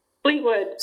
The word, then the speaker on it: Fleetwood: Fleetwood, Sinclair. Like Fleetwood Mac.